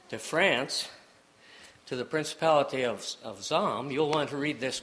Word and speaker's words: Salm: It's spelled S-A-L-M, pronounced Zalm, with a Z, or as in you would say in the U.S., a Z.